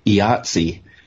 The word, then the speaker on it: IATSE: IATSE, announced that the union has set a strike deadline of Monday morning.